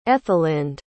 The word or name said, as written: ethelinde